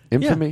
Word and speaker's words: infamy: Did I say that right? Infamy. – Yeah, that's right.